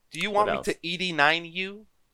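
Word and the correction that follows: ED-209: You're saying it wrong every ED209.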